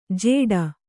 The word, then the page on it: ಜೇಡ: jēḍa